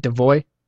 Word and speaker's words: DeVos: Dude, Brennan, the report was so precise that it says the 86-year-old DeVoe, is it DeVoe? D-E-V-O-S? – Yeah, sure.